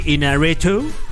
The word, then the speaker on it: Iñárritu: Iñárritu. Yeah. No sé si lo estoy pronunciando bien. – No, muy bien.